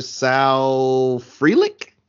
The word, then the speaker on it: Frelick: And I'm like, Dominic Fletcher, Sal Frelick. Am I saying that right? – Yeah.